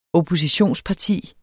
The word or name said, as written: oppositionsparti